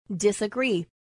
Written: disagree

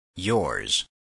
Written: yours

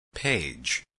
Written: page

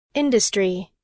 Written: industry

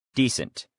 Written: decent

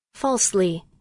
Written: falsely

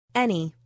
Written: any

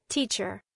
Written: teacher

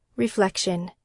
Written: reflection

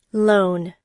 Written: loan

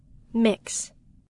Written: mix

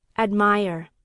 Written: admire

